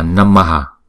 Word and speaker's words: namaha: Đứng một mình thì chữ này chúng ta đọc là Namaha.